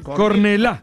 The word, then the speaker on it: Cornellà: Cornellà. – ¿Qué? – Con acento en la Cornellà.